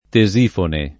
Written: Tesifone

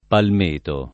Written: palmeto

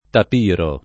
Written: tapiro

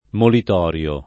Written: molitorio

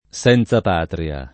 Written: senzapatria

